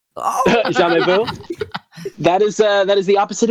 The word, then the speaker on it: jamais: I've always just said Jamais. – Jamais. Yeah, it's French. You leave off the last letter, and that's how you pronounce it.